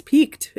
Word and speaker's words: piqued: it's not always spoken idioms, but um, when someone says it piqued my interest, it's pronounced the same. It's peaked. It's you know, it's P I Q U E D is how it's supposed to be spelled.